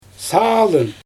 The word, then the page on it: saalyn: Xyzyl